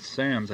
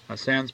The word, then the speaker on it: Sannes: District Court order was based on charges brought yesterday by David Sanes, I guess is the way it's pronounced, S-A-N-N-E-S, charges against Stan Pitkin, U.S.